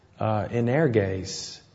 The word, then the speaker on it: energes: It's energes. It's spelled E-N-E-R-G-E-S. Kind of sounds like energy, doesn't it?